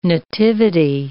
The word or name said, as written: nativity